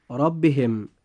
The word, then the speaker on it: ربهم: نجد أن الراء في كلمة ربهم جاءت مفتوحة، فيجب أن تفخم هكذا: ربهم.